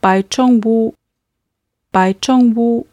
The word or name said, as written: baichongwo